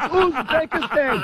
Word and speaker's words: Uzbekistan: It's Uzbekistan. Jesus, no, it's Uzbekistan. It's not that hard, mate.